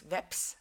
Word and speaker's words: veps: In Dutch, it's vesp. Yeah, vesp. And in Norwegian, it's veps. So you turn around two letters, and I didn't notice because that's just how I always pronounced it.